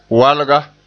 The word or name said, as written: walga